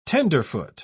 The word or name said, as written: tenderfoot